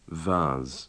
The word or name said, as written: vase